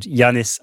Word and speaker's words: Giannis: There is a basketball player called Giannis Antetokounmpo, Greek guy. Obviously, silent G.